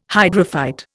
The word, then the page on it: hydrophyte: Indian